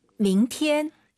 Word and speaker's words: 明天: 자, 내일을 중국어로 明天. – 이성 출발점이 낮게 가야 돼요. 밍티엔 이게 아니라 밍티엔.